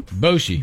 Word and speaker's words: Bochy: This news dropped a few days ago, but we didn't really talk about it, but San Francisco Giants manager Bruce Bochy, is that how you say his name? – Bochy.